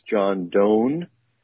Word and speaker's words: Donne: So it's by, I believe his name is pronounced John Donne. And he wrote, "For Whom the Bells Tolls." – Oh, John Donne. John Donne, yes. – John Donne. I wanted to say Donne, but it's spelled D-O-N-N-E.